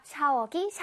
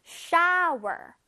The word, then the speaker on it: shower: 그렇다고 샤워, 샤워 이러면 절대 안 되겠지. 선생님, 잘 들어봐. shower. shower. 자, 끝에 R 발음 나는 거 들었어? shower. shower. No. shower. shower. 입을 크게 벌려야 돼. shower. 오케이. 자, OW가 들어가면 아우 소리가 나요. 아우. 그래서 shower. 오케이?